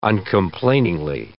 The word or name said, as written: uncomplainingly